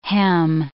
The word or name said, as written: hem